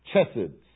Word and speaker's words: chesed: In Hebrew, chesed, C-H-E-S-E-D, is the way we spell that in English.